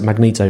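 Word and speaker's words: Magento: Is that how you pronounce it? Magneto. Mm-hmm. Magneto.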